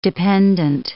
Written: dependent

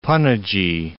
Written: Panaji

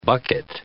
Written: bucket

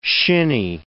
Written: shinney

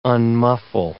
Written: unmuffle

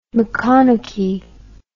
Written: maconochie

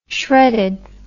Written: shredded

